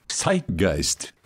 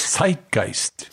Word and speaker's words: Zeitgeist: Zeitgeist. – Zeitgeist. Not Zeitgeist, as is commonly spoken by a lot of Englishers. Mm-hmm. But the German pronunciation of the word is Zeitgeist.